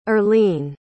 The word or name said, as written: earleen